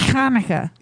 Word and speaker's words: Hanukkah: I thought it is Hanukkah. – You said it right.